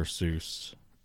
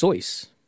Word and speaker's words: Seuss: The adulterer, Dr. Seuss. – That was the before times. Did you know it's supposed to be pronounced Soyce? – No. – As in rhymes with rejoice.